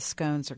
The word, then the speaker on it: scone: I don't know if the Nelson makes their own pastries, but the scones are great. They are. – Or as a barista in town who's from England told me once, it is pronounced scone.